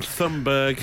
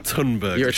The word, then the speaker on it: Thunberg: I think going forward in 2020 now, I'm going to start calling her Thunberg because that seems to be the proper way of pronouncing it. – You plan on talking about her a lot, just so I know. – Before 2020, I said Thunberg. – Yeah. – Now I'm going full on Thunberg.